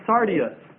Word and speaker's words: Sardius: It's talking about the Sardius Stone. The Sardius Stone. The Sardius Stone, and this is spelled S-A-R-D-I-U-S.